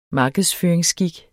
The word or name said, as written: markedsføringsskik